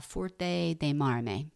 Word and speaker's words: Forte dei Marmi: And Forte dei Marmi.